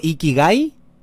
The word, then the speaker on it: Ikigai: Ikigai, sí.